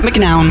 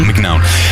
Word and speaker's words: McNown: Max McNown. Zo spreek je hem juist. McNown? – McNown. – McNown.